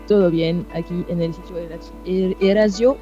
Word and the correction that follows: eriazo: ¿Cómo se dice? – Eriazo. – Eriazo, ok.